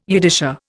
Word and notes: Yiddisher: Indian

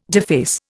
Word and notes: deface: Indian

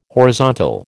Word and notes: horizontal: American English

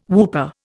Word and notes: whooper: Indian

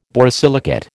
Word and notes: borosilicate: American English

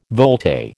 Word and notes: volte: American English

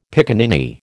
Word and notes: piccaninny: American English

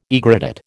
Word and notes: aegrotat: American English

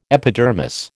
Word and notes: epidermis: American English

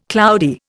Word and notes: cloudy: Indian

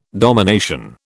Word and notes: domination: British English

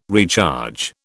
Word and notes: recharge: British English

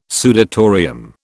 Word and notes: sudatorium: British English

